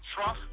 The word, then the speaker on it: Truff: If you're an artist and you hit me up and you say, hey, DJ Truff, T-R-U-F-F, can you listen to my song?